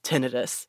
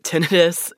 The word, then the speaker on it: tinnitus: But one of the most common symptoms to pay attention to is tinnitus. Yeah, it's pronounced tinnitus, not tinnitus. Common mistake.